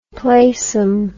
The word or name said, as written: playsome